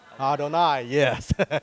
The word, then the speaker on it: Adonai: But when you see Yod, Hey, Vav, Hey, how do you pronounce it? Adonai, yes. Adonai, okay.